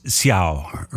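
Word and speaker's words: Céu: Brightest stars from São Paulo's Sambatronic scene is Xiao. Her name is spelled C-E-U.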